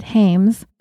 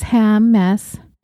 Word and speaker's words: Hammes: Last time I said Hames, but it's Hammes. Ham as in smoked ham. Mess as in my life's a mess.